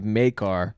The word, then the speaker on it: Maekar: We find out that Aemon is the son of Maekar, I believe is how it's pronounced, M-A-E-K-A-R.